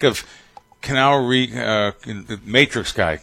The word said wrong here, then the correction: Keanu Reeves: Am I saying his name right? Keanu Reeves? – Keanu Reeves. – Keanu. Keanu Reeves,